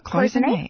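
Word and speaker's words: cloisonné: Cloisonne.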